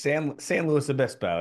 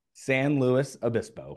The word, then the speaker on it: San Luis Obispo: Am I saying that right? – San Luis Obispo. It's close. It's close. – I'll say it one more time. – San Luis Obispo.